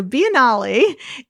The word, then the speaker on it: Biennale: The Venice Biennale, which is kind of spelled like, you mean like biennial, but it is Italian. So Biennale is the world's longest running large-scale survey of contemporary art.